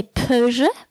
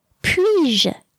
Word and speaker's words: puis-je: Now, if you want to do the inversion of the subject and the verb, it also doesn't work to say peux-je, peux-je. It's two weak sounds. So you may know that one. How would you say that? Puis-je? Puis-je?